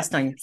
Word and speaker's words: Bastogne: Bastogne, yeah. – Did I say that right? – Yeah. Bastogne.